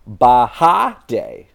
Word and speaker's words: Bahade: And the way you pronounce his name is Bahade. So we can play with that a little bit. Bahade is how it was phonetically spelled out.